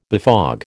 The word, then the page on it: befog: American English